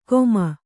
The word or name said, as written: ಗೋಮ